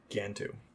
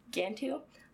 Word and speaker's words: Gantu: Gantu. – Gantu.